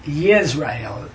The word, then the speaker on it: Yisrael: And when I try to say Israel, I'm surely trying to say Yisrael. Y-I-S-R-A-E-L. Yisrael. That's a true pronunciation.